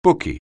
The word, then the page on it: bookie: anglais